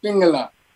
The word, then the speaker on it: Pingala: Is it Ida and Pingala? How would you say it? – That's good. Ida and Pingala.